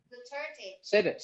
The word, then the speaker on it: Duterte: Den handler om Duterte. Du ved... – Ja, præsidenten. – How do we try to say Duterte? – Duterte. – Say it again. – Duterte.